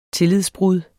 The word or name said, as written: tillidsbrud